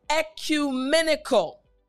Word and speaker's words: ecumenical: Yes. E-Q. Excellent. So let's say the entire word. Ecumenical. Good. Last time, ecumenical. Great job.